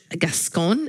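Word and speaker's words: Gascón: My good news machine item is that uh, the Los Angeles uh, uh, District Attorney um, George Gascon. – Is that how you say his name? Mm-hmm.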